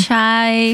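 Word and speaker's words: Tocai: I don't know if it's Tokai or Tosai. – Tokai Friulano.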